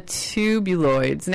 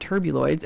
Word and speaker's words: Tubuloids: And so this is the Tubuloids. Now, if you want to know how to spell it, it's T, like tube. T-U-B-U-L-O-I. L-O-I. That's right, because I'm a skinhead. T-U-R-B. Not turbuloids. It's the Tubuloids. T-U-B-U-L-O-I-D-S.